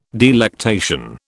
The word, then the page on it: delectation: British English